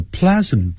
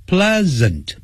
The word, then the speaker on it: pleasant: John has a pleasant personality， personality 就是个性，这种字比较长，那我教大家发音的时候由后往前念， nality， nality， personality， personality，你看马上就会了。好，个性 pleasant Pleasant，不要念成 pleasant， pleasant 就是令人愉快的，让大家会觉得很乐意跟你在一起的，爽朗的个性。